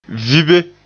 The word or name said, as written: vibe